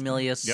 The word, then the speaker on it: Milius: The John Milius, is that how you say it? – John Milius, yep.